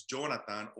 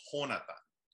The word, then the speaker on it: Jhonatan: My name is Jonathan, but I spell it different. So in Spanish, it's Jonathan or Jonathan. So I spell it the J-H-O-N-A-T-A-N.